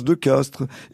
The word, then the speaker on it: Castries: Bon, euh, on sait aussi, par exemple, alors ça, je, je ne savais pas avant d'écrire ce livre, hein, que De Castries se prononce De Castres, et qu'en principe, euh, De Guise, hein, celui euh, dans l'histoire donc qui a été assassiné, eh bien, se dit De Guise.